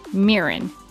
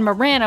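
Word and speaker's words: Myr'nn: Because I've been trying to all season, like, consciously say Mirin. Say Mirin, Mirin, Mirin, Mirin. And then when she said Marin, I was like, what?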